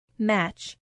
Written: match

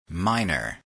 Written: minor

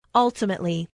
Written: ultimately

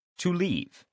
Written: exit

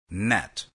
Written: net

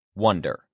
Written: wonder

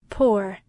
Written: pour

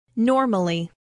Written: normally

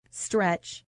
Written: stretch

